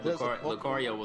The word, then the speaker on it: Lucario: Lusario, I don't even know if that's how you pronounce it, the Pokemon is coming to Smash Brothers Wii U. All right, eh? Huh? Anybody care? – Wasn't he was on Brawl, right? – Was he? – Yeah, Lucario was on Brawl. – That's how you pronounce his name, Lucario? – Yep.